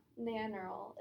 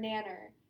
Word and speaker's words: Nannerl: I'm pretty sure is how that's Nanorl? Pronounced. Nanorl. It's like Nana RL. How's or it? Nanner. It's like N-A-N-N-E-R-L,